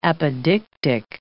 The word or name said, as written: apodictic